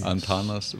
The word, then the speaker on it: Antanas: Antanas Vainius.